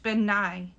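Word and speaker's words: bean nighe: The Scottish Benai, that's how they say it, are often considered Scottish banshees.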